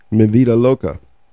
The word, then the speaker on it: Mi Vida Loca: Mi Vida Loca.